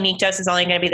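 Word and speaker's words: Nyktos: Nyctos is how it's said in audible.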